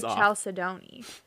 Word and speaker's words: chalcedony: It's spelled like Chalcedony, but it's Chalcedony.